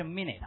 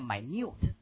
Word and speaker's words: minute: 不是 minute 啊，念啊，应该念 minute，这么念啊， minute， right？ OK，细致的意思， minute， right？ OK，分钟读啊，完全一样写法，但是读音不同， minute，都详尽的意思， right？